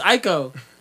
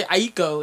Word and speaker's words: Aiko: It's Aiko. I used to say Aiko, and I realized that there's no other I, you know. It's Aiko.